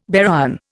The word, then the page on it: baryon: Indian